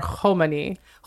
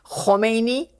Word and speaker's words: Khomeini: Or Khomeini. – Khomeini. Khamenei. – Khomeini. Khamenei. It's like ebony ivory.